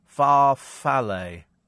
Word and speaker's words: farfalle: And apparently Sabrina says this is pronounced Farfalle.